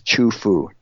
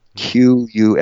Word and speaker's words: Qufu: It's pronounced Qing, but yeah, this is Chufu. Qufu. It used to be spelled differently, but yeah.